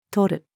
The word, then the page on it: とる: female